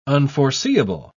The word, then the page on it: unforeseeable: British English